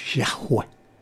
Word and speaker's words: Yahweh: Claro, en el Antiguo Testamento, en el original, no es Jehová, son sílabas, YHWH, que se pronuncia Yahweh.